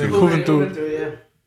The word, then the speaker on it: Juventus: I don't know how to say it, but Juventus. – Juventus.